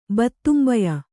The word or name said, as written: ಬತ್ತುಂಬಯ